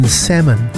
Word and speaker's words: salmon: Isn't it interesting how the L in salmon is silent, but the L's in salmonella are so loud? – Is that interesting?